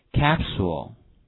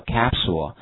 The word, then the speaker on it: capsule: 好，胶囊这个词的话非常简单哈，大家注意一下，但是它读音 ,SULE, 这个 U 发 U 的音，跟我读一下 capsule, OK, 这就是胶囊叫 capsule, OK, 好，那么下面看一下，我们中国的话是以中药著称，对不对？